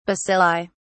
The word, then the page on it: bacilli: British English